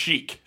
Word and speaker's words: Iron Sheik: Iron Sheik put out, that's how you say it, right? Iron Sheik? Cheek? – Looks like Sheik. – Sheik, okay.